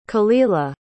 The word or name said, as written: kalila